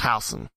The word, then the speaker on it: Housen: Yes, I know it looks like Houston, but it's pronounced Housen.